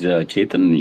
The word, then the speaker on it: Chetan: This is Chetan.